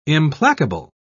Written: implacable

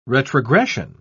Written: retrogression

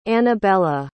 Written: anabela

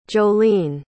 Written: jolleen

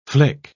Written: flick

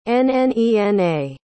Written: nnena

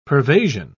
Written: pervasion

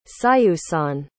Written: siusan

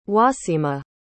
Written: waseemah

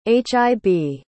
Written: hib

